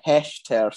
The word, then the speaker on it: Hechter: The stripe in the middle, the Hector stripe, did you say? – See, funnily enough, I actually Googled how to pronounce it because I thought if I'm going to come on here and be a smart ass, I better have all my facts and pronunciations right. So it's the Hechter stripe.